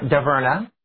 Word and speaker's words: DeVarna: English pronunciation of her name is Daverna. The French pronunciation, which she does not favor, I won't attempt to say. Most people butcher it, so she chose Daverna as her American name, because it's very French.